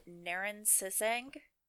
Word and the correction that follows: Naran Setseg: You're very close. Yeah, um, I think I was pronouncing it Naran Setseg. – Naran Setseg. That makes that that rolls off the tongue more fluidly than the way that I was just pronouncing it.